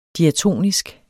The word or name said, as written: diatonisk